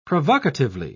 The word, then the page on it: provocatively: British English